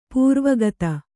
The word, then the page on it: ಪೂರ್ವಗತ: Kannada